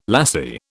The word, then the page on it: lassie: British English